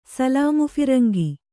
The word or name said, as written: ಸಲಾಮುಫಿರಂಗಿ